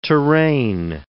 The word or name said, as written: terrain